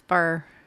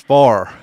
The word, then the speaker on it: fire: It's fur. – No, it's not fur. It's far. – Far. – Started like, far. – It sounds like fur. – Yeah. No, it's like going like a far, just like far.